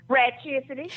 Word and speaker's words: Ratchcity: Ratchcity.